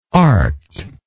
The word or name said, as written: arcked